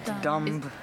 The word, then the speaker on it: dumb: Do you know, like, dumb, the word dumb, D-U-M-B, in English? – Yeah, dumb. – Dumb. – No, it just means stupid, D-U-M-B, dumb. – Dumb. – Dumb, D-U-M-B, but you don't pronounce the B, dumb.